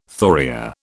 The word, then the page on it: thoria: British English